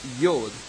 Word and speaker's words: yod: Now, the word jot in Hebrew is pronounced yod. And yod is the smallest letter in the Hebrew alphabet. Now, yod is equivalent to Y of English alphabet, ya sound.